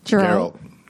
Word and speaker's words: Geralt: Did I pronounce that right? – Geralt.